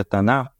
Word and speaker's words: Tanakh: Quando nós vamos para o Taná, que se escreve Tanakh, mas se pronuncia Taná, a Bíblia dos judeus, eles têm os mesmos livros do Antigo Testamento que o nosso.